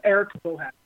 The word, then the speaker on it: Eric Bohacek: It's Eric BOHACEK.